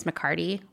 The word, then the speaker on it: McCarty: This is very serious to me because while my last name is McCarty with no H, I'm frequently called McCarthy, even by people who have known me for a long time.